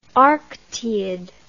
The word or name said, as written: arctiid